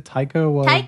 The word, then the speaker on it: Taika Waititi: Is it Taika Waititi? – Taika Waititi.